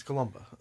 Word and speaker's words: Columba: I feel like I'm saying that wrong, but it's not. – It just sounds like a weird name. – Yeah, it's sounds like made up. – It's C-O-L-U-M-B-A, so it's Columba.